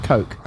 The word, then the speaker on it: Koch: That's Coke, right? You pronounce it Coke.